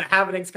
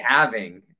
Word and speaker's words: halving: They're like, oh, the halvening's coming up, and everyone asks, is the halvening, you know, also, is it halving or halvening? I always say it wrong. – I would say halving. – Okay, I think that's right.